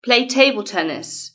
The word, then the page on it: play table tennis: British English